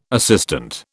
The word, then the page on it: assistant: British English